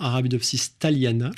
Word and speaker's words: Arabidopsis thaliana: L'arabette des dames, également appelée Arabidopsis thalania. – Votre réponse, sur cette arabette des dames? – Alors, l'arabette des dames, effectivement, Arabidopsis thaliana. Je corrige le petit défaut de prononciation.